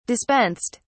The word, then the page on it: dispensed: British English